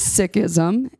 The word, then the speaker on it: Sikhism: So it is Sikhism, and when you say it plural, it's six, like the number.